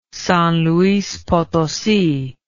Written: San Luis Potosi

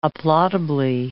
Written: applaudably

